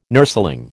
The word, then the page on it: nurseling: American English